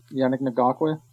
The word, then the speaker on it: Yannick Ngakoue: How do you say his name? – Yannick Ngakoue. – Ngakoue. Yannick Ngakoue.